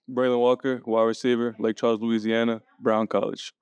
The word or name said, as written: Braylen Walker